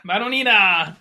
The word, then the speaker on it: Madonnina: Madonnina!